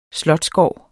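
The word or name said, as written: slotsgård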